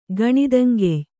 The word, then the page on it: ಗಣಿದಂಗೆಯ್: Kannada